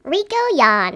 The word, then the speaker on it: Rico Yan: Rico Yan.